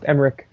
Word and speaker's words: Emmerich: So I really like how Emmerich, by the way, it's pronounced Emmerich, how Emmerich actually sees the possibility of having Will back and is writing these two scripts regardless.